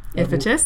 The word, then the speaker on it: Iphitus: So she mentions here that Heracles has killed the mighty, I'm going to blank on the pronunciation, Iphitus? – Yep.